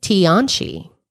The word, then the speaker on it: Tianchi: Lake Tianchi. I think I'm saying that right. – Yeah.